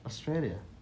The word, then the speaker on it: Australia: Another random fact is the name Australia combines three A has obviously the name Australia contains three A's and each A is pronounced differently. – Yeah. – Australia.